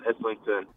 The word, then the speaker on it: Islington: And if memory serves, you were born in Islington, right? That's how you pronounce it? – Yeah, I was born in Islington.